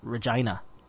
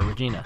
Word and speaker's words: Regina: Regina. Or Regina, depending on how you... his name is Jamie. He's from Regina, Saskatchewan. Or Regina, depending on how you want to pronounce it.